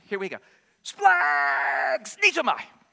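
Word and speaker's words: splags. Needs-o-my (σπλαγχνίζομαι): It's a really funky word, and it is pronounced basically splags. Needs-o-my.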